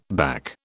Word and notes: back: British English

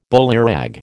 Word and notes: bullyrag: American English

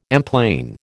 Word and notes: emplane: American English

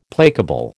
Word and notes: placable: American English

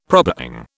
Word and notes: probang: British English

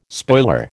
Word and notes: spoiler: American English

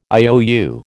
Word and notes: IOU: American English

